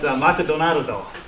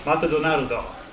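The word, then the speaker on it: Matadonardo (マクドナルド): We read this, Ma, ku, do, na, nu, do. What does that mean? – McDonald's. – Very good. That's Matadonardo. Matadonardo.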